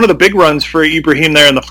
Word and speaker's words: Ibrahim: Speaking of which, um, the holes that that group was able to open up for uh, Mo Ibrahim, or I'm sorry, Ibrahim. I kept hearing it on, on Saturday that I've been saying it wrong.